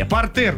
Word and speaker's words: партер: Далее, партер. Ну, конечно, не партер.